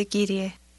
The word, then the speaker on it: κύριε: Χαίρετε, κύριε. Κύριε. – The Greek words sir and ma'am are very similar, but in each word a different syllable is stressed.